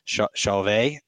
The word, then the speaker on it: Chauvet: I believe it's Chauvet is how you pronounce it.